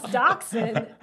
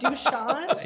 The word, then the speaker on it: dachshund: I just laughed at Randy because you wouldn't pronounce dachshund. You said Dushan.